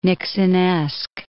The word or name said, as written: Nixonesque